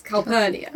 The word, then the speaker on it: Calpurnia: It's not whatever I said it was. Calperina. It's Calpurnia.